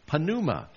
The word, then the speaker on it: pneuma: The word for spirit is pneuma. Like P is silent when we use it for pneumatic drill, anything to do with air, because that's what spirit was.